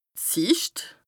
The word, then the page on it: d' Sìcht: Bas-Rhin; Strasbourg